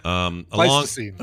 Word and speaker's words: Pleistocene: Pleistocene.